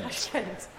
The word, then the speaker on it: Tashkent: Tashkent.